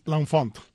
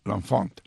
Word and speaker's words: L'Enfant: ¿Cómo se pronuncia? – L'Enfant. – L'Enfant.